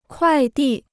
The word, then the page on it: 快递: kuài dì